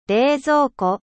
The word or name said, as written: Refrigerateur